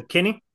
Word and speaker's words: Kinne: It's funny because the way everybody says it, it sounds like Cartman talking to his friend Kenny, Kenny, GJ Kinne.